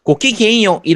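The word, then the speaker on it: ごきげんよう: 그러니까 귀족 말투에 약간 조신한 아가씨 말투로 실제 발음은 고키갱요 이런 느낌으로 발음을 하는데 실제로 일본 내에서는 거의 안 쓰는 단어입니다.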